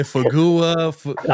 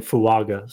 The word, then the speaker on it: Fuaga: Fagua. – I know. Like, so it's Taliese and then Fuaga.